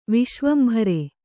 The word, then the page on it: ವಿಶ್ವಂಭರ: viśvambhara